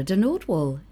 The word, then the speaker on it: de Nordwall: Sarah de Nordwall.